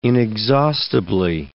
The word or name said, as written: inexhaustibly